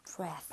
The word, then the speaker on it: breath: Breath. – 발음도 breathe가 아니라 breath.